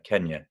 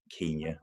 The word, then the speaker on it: Kenya: He grew up in Kenya, Kenya, wow. Dependent on the different people, but whoever wants to pronounce it.